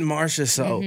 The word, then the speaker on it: Marchessault: Jonathan Marchessault? – Jonathan Marchessault.